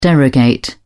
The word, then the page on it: derogate: British English